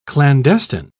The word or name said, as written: clandestine